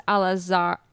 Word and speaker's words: Alazraqui: And the person who was the voice actor was Carlos Alazar. Wow. I am so good at pronouncing last names. Carlos Alazraqui.